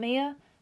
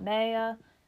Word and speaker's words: Mea: And spelled M-E-A. Pronounced May. Not Mia. Not Maya.